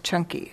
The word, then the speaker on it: Canki: So one of my favorite suttas that addresses this is this Buddha's teachings to someone named, it's spelled C-A-N-K-I, but it's pronounced Chunky, apparently.